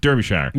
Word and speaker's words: Derbyshire: In England, a police officer in Derbyshire, which I'm sure I'm saying wrong, somebody's going to write in and tell me it's pronounced Derbyshire. Yeah, no, that's about right.